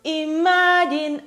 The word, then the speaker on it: imagine: Imagine all the people. – So this word actually contains a schwa.